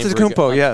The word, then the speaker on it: Antetokounmpo: Antetokounmpo, Capoco.